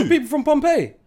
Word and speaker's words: Pompeii: P-O-M-P-E-I-I. – Oh, is it? – Yeah. So how would you pronounce that? Pompey. – Yeah, that's how you pronounce it.